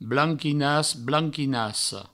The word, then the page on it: blanquinas, blanquinassa: Provençal; blanquinas, blanquinasso